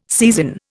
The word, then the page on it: seisin: Indian